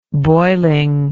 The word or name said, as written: boilingly